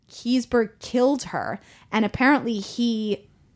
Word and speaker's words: Keseberg: Kiesberg. – K-E-S-E-B-E-R-G. Kiesberg. I think that's how you say it.